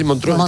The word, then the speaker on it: Montreux: Pues rememora un incidente ocurrido en Montreux, en Suiza. ¿Se dice así? – Montreux. – Eso es.